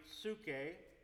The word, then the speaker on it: psyche: The other is spelled P-S-Y-C-H-E, pronounced in Greek, psuche.